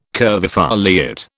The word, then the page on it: curvifoliate: British English